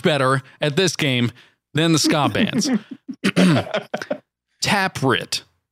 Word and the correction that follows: Taproot: Do you not know how to say the band name Taproot? Taproot.